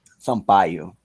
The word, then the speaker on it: Sampaio: Sampaio.